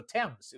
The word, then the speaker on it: Thames: Like the later ones, like what was that, ITV or no, Thames? It was Thames, right? Or as I pronounced it at the time, Thames, because I had no idea.